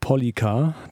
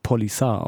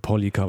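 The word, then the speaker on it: Poliça: Weiter geht's hier mit immer noch Frauenpower und zwar mit Polika, das C in diesem Fall mit einem kleinen Apostroph unten geschrieben, vielleicht auch Polisar ausgesprochen. Ich habe da einmal hier und das mal gehört. Ich glaube, Polika, das habe ich auch damals schon zum Debütalbum gesagt, welches vor gut anderthalb Jahren rauskam.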